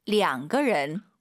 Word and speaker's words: 两个人: 两个人. – 우리도 이명이라고 안 하잖아요. 두 명 그러죠. 얼이 아니라 량.